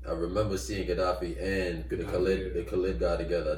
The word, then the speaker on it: Gaddafi: Gaddafi? – Muammar Gaddafi, yeah.